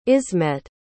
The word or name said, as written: ismet